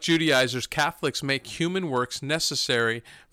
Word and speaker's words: Judaizers: Paul wrote Galatians to combat the Judaizers. Did I say that right? – Yes.